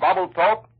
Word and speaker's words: Babelthaup: Marine fighters have bombed and strafed supply and ammunition dumps on Babeltoke, the largest enemy-held island in the Palau group. That island is spelled B-A-B-E-L-T-H-A-U-P.